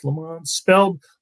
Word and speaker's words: Lammons: Opening kickoff and uh, Chris Lammons. Am I pronouncing that right? Lamons, Lamons, Lamons, spelled Lam- Lamons, pronounced Lamons.